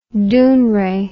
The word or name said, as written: Dounreay